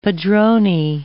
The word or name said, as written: padroni